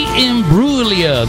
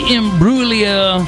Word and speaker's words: Imbruglia: Natalie Imbruglia. There's a G in there, but the G is silent. Natalie Imbruglia.